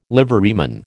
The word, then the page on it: liveryman: American English